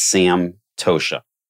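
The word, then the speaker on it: Santosha: It's pronounced Sam. Tosha. And what that loosely translates to is complete contentment.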